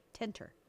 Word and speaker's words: tenter: Tenterhooks is a hook used to fasten cloth on a drying frame or tenter. – There you go. So it's tenter, not tanter. – Yeah, T-E-N.